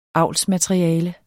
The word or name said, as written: avlsmateriale